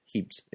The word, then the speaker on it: Hibs: Me, Hibs, pronounced Hebs in Italy.